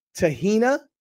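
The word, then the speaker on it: Teheina: Her name is, and I'll have to, I hope everybody can help me pronounce it, Tahina. It's T-E-H-E-I-N-A, Tahina or Tahina. And her last name is P-A-O-P-A-O, Papow.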